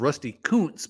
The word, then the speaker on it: Kuntz: I don't know if you played with Rusty Kuntz, but uh, that's the actual pronounce, pronunciation of it.